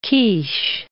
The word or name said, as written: quiche